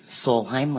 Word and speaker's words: Solheim: My name is Mary Solheim. Last name is spelled S-O-L-H-E-I-M.